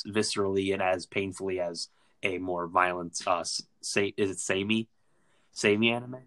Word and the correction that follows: seinen: Seinen? – I don't know. How did you pronounce it? Samey? – I think it's pronounced seinen because it's an N.